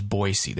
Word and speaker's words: Boise: And as I have learned, the correct pronunciation is Boise.